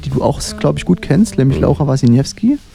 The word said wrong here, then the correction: Wasniewski: Ich hoffe, ich habe ihren Nachnamen richtig ausgesprochen. Wasniewski? Ja, Wasniewski. Genau.